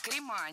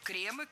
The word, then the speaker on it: кремы: Крема, неверно. Вспоминаем слово «благо», «благом», «благами». И здесь все под копирку. Кремы, кремы, в кремах. Ударение сохраняем на первом слоге во всех формах.